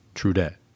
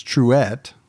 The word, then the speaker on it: Truett: Trudette. – It's Truett. – Oh, like Trudette? – No, there's no D in it.